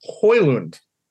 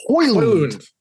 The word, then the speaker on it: Højlund: It's Rasmus Højlund. – Højlund. – Højlund. At least that's what the video of him telling people how to pronounce his name told me.